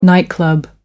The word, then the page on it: nightclub: American English